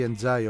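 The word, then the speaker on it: Ndiaye: είναι πλοκάμια του Ενδιαγέ ή Ενζάι, όπως το προφέρει ο ίδιος, έτσι λίγο αμερικάνικα.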